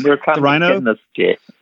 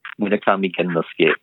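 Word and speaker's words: Murakami Gennosuke: Murakami Genosuke? Am I anywhere close? – Oh, Murakami Genosuke. Yeah, Genosuke. Murakami Genosuke.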